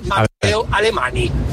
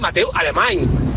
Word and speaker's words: Alemán: Una cosa más, Radio Marca, ¿le podéis decir a vuestros colaboradores y tertulianos que no se dice Mateo Alemani? Se dice Mateo Alemán, como si acabaras con ñ. Mateo Alemán.